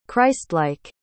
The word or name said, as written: christlike